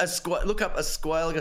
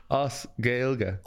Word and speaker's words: Gaeilge: Os Gaeilge. – Os Gaeilge. – I love it. You just made up. A Squalga. – Hang on. So it's Os. – A-S is Os. – Os. Like Aussies.